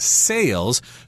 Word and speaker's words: sale: They sell things at special prices at sales. So be careful about the pronunciation of those vowels there sell and sale.